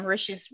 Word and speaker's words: Mauritius: I read an article, or was it in the movie, that Mauritius rhymes with delicious?